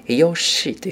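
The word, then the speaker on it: 游戏: えゲームはですね、ヨシーというふうに発音します。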